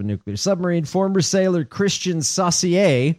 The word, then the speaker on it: Saucier: S-A-U-C-I-E-R. Oh. Saucier, it might be. – Oh, Saucier! – I'm giving him the French pronunciation.